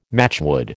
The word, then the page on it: matchwood: American English